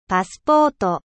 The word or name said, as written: passport